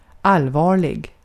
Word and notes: serious: [ˈsɪər.iəs]; /ˈsɪɹiəs/; /ˈsɪəɹiəs/

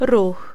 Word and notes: trafic: /tʁa.fik/; France

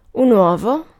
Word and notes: uovo: [ˈwɔvo]

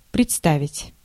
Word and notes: представить: /prʲɪtˈstavʲɪtʲ/

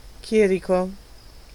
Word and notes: prêtre: Paris; [ɛ̃ pʁɛtʁ]

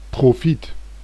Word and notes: profit: /ˈprɒ.fət/

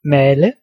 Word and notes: moudre: France; [mudʁ]

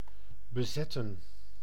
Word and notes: occuper: France; [ɔ.ky.pe]

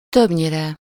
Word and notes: többnyire: /ˈtøbɲirɛ/

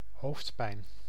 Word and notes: hoofdpijn: [ɦoːfd.pɛjn]; /ɦo:fd.pɛjn/; /ˈhoftpɛin/

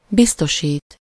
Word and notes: biztosít: /ˈbistoʃiːt/

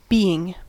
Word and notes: being: /ˈbiː.ɪŋ/

